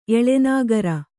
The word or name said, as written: ಎಲೆನಾಗರ